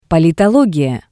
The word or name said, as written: Politologiya